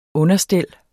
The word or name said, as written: understel